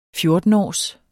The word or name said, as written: fjortenårs